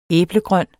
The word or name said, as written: æblegrøn